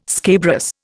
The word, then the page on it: scabrous: Indian